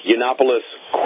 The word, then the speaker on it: Yiannopoulos: At number 8, Yiannopoulos quits Breitbart.